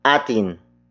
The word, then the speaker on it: atin: The next word is atin. Atin. Atin. Atin is spelled as A-T-I-N.